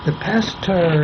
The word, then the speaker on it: Pasteur: Now, the Pasteur Institute, which is, by the way, the way you pronounce it, it's pronounced just exactly as in stir your coffee, because it's French.